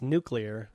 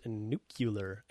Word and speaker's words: nuclear: Is it nuclear or nuclear? – Well, it's nuclear is how you're supposed to pronounce it.